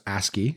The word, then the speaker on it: ASCII: ASC11 or ASCII. – Well, yeah, that's what she puts in brackets, pronounced ASCII.